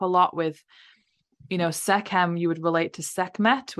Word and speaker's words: Sekhem: So it's pronounced Sekhem. It's with the K.